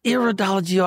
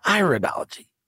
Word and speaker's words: iridology: First of all, you do a thing called, well, is it iridology or iridology? Which one it's is it pronounced? It's, it's both. – both. Okay. – Yeah, it's both.